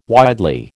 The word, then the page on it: widely: American English